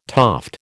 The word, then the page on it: toft: American English